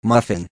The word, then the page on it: muffin: American English